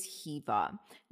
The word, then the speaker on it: Hiwa: Okay, so my name is Hiva. Now, I was born in Germany, and in German, W's are pronounced like V's.